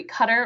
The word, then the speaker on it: Qatar: Yeah, Qatar. You can say it either way, Qatar or Qatar.